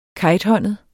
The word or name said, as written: kejthåndet